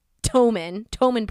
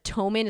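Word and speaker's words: ptomaine: This time, he supposedly had died from something called, and I don't think I'm pronouncing this correctly, because it's spelled P-T-O-M-A-I-N-E, P-T, the little, like, consonants right next to each other. So I think it's called Tomin poisoning. I think the P is silent, but I'm okay.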